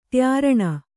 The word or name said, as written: ತ್ಯಾರಣ